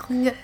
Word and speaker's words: Tlingit: I'm sorry because I'm definitely going to butcher this, the Tlingit. – Tlingit. – Tlingit. – It's pronounced Tlingit. – Okay, there we go.